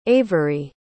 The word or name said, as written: averi